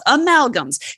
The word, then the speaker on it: amalgams: I can never say amalgams. It's fillings. – Perfect. Perfect. But you said it right. Amalgams is exactly right.